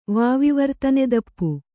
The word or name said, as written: ವಾವಿವರ್ತನೆದಪ್ಪು